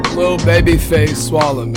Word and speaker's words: Lilbabyfaceswalla: Lilbabyfaceswalla, man.